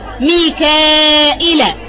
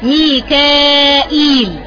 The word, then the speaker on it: ميكائيل: مثال مثلا كلمة ميكال، كلمة ميكال تقرأ بكذا كيفية، ميكائيل، ميكال، ميكائيل، تمام.